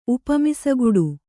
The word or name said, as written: ಉಪಮಿಸಗುಡು